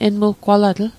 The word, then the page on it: enmuggwalatl: Mi'gmaq